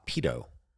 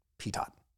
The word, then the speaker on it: pitot: P-I-T-O-T, PTOT. – Pedo. – No. – Pedo tubes. – PTOT, like tater tot. – Oh, I know what you're saying, but you're saying it wrong. It's pronounced pedo.